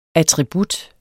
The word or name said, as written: attribut